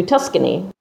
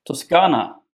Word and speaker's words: Toscana: And I'm going to Italy with two very good friends that you both know, Girls Getaway, and we're going to Tuscany. – Oh, wow. – Toscana. – Oh, is that how you say it? – Yes, you will never get tickets if you're going to say it like that.